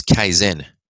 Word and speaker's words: Kaizen: So there was a Japanese principle, which is Kaizen. It's spelled K-A-I-Z-E-N. Kaizen, the definition of it is the practice of continuous improvement.